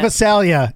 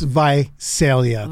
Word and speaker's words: Visalia: It's not Visalia. It's Visalia. – Thank you, Visalia.